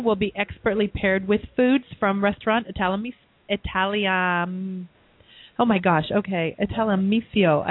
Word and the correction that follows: Italianissimo: I never pronounced that one properly. They're in Woodinville. I have had their food. Great, great food. Italianissimo.